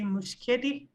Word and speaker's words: Muschietti: And the guy directing it, Andy Muschietti, I hope I'm pronouncing his name wrong.